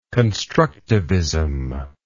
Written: constructivism

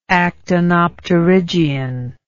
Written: actinopterygian

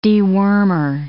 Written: dewormer

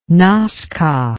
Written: nasca

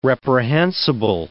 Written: reprehensible

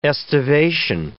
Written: aestivation

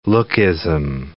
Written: lookism

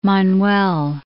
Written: Manuel

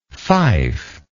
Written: fifer

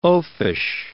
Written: oafish

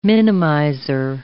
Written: minimizer

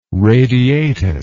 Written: radiatus

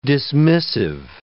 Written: dismissive

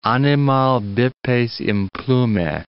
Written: animal bipes implume

